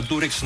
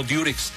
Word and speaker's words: Durex: Así se llama Durex, no Durex. Lo que pasa es que nosotros pronunciamos, al decir Durex, pronunciamos la U como se pronuncia en inglés, U, ¿no?